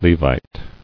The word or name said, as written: Levite